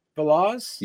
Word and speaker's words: Veloz: Lucy Veloz, Veloz. – Yes, like uh, Velocity.